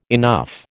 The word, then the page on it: enough: American English